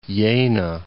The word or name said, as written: Jena